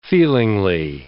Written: feelingly

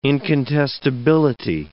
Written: incontestability

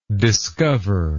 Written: discoverably

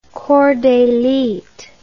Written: corps d'élite

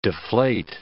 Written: deflate

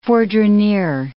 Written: fourdrinier

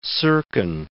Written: Serkin

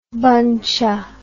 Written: buncha

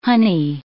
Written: honey

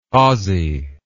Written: Ozzie

